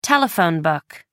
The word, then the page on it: telephone book: British English